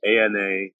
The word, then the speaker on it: Anacleto: Okay, and your name is ANACLETO, Anacleto and Rizzo, RIZZO, is that right? – Yep.